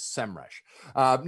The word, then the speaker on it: SEMrush: Their logo now starts with a capital S, lowercase em, and then rush, and an actual audio going, the way to pronounce this is SEMrush.